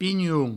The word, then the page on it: pinhon: pignoun